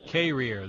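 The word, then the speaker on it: Carriere: Carrier, that's right. That's how everybody pronounces it around there. – Yeah, apparently you have to have lessons if you move there on how to pronounce it. And the people who live there pronounce it Carrier.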